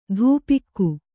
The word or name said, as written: ಧೂಪಿಕ್ಕು